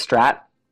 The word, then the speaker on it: Strat: It's named after Stratocaster, which is a type of guitar. So yeah, I think it's just a coincidence that it rhymes. Strat and Bat, yeah.